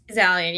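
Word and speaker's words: Zaillian: Zalian. – Zalian, yeah. Pronounced Alien.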